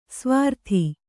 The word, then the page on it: ಸ್ವರಿತ: Kannada